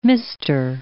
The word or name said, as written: Mr.